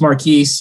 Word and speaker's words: Markquis: It's just Marquise. He told me his dad was named Marcus and his mom's name starts with a Q. They decided they needed to combine it somehow with his name, so they spelled his name Marquise, M-A-R-K-Q-U-I-S.